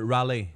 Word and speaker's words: Raleigh: Là, à Riley? – Oui, il est en Californie, je pense. Là. Pas Californie, en Caroline, excuse. – Oui, Raleigh. – Raleigh, voilà. Ouais, je l'ai mal prononcé.